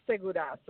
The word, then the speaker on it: Segura: And you're pronouncing it correctly. It is Segura, so your pronunciation is great.